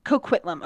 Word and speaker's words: Coquitlam: how do you say that it's coquitlam coquitlam okay i didn't know for sure